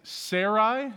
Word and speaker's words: Sarai: His wife's name is Sarai, is how you pronounce it, but her name's going to be Sarah.